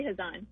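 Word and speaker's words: Khazan: Well, how do you pronounce it, just out of curiosity? – I say Hazan. – Hazan. Okay, then I'm saying Hazan from now on.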